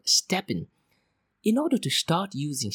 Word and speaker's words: STEPN: Now personally, Claire, I am not a fan of this app called Steppin. Now it's spelled as S-T-E-P-N.